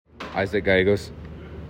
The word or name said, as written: Isaac Gallegos